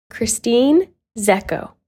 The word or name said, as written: Christine Zeko